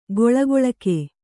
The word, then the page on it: ಕೊಳಕೊಳಕೆ: koḷakoḷake